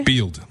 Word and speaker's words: Bild: Bild.